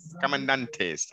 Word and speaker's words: comandantes: So, do any of the cartel, the commandante. How do you say it again? – Comandantes, that's it, sorry.